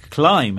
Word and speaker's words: climb: Well, climb has a silent B at the end and knee starts with a silent K.